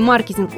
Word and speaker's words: маркетинг: Все дело в том, что в английском языке в слове «маркетинг» ударение однозначно ставится на первый слог.